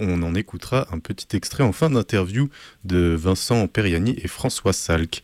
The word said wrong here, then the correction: Peirani: Peirani, pardon, excusez-moi, j'ai écorché le nom.